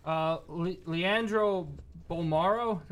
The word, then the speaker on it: Leandro Bolmaro: Malik Beasley, Patrick Beverley, Jared Vanderbilt, Leandro Bolmaro.